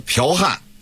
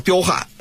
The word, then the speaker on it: 剽悍: 还有这个剽悍啊，很多人都是彪悍，是吧，它就类似于就这种错字发音的啊造成的这种误会。